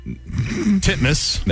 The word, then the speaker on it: Titmus: Pronounce it the way it's spelled. – Ariane Titmouse. Titmus. – There you go. – Is that how you say it? – That is legitimately how you say it.